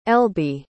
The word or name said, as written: elby